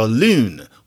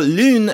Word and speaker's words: lune: And if you're talking about writing, well, lune has that E at the end, which is often silent or it can be pronounced in some circumstances.